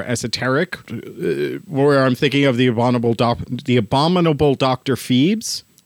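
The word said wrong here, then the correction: Phibes: Or Fives, mm-hmm.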